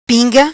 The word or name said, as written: Pinga